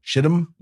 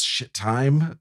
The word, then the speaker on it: Shittim: Shittim? – Yeah. – I certainly hope so. Okay. I don't know. Maybe it's shit time. It's spelled pretty phonetically, though. It's S-H-I-T-T-I-M.